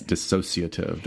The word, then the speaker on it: dissociative: How do a lot of people pronounce it? – Dissociative disorder. And it's not. Right. It's dissociative. There's not that extra syllable.